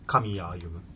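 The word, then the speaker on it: 狼谷歩む: 狼の谷を歩む者と書いて、神谷歩む。超かっこいいよね。かっこいいね。狼のことを神って読ませてんだ。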